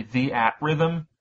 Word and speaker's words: Theatrhythm: If you're going into this without really knowing what the hell Theater Rhythm is, the way the name is spelled is ridiculous because it's actually the at rhythm.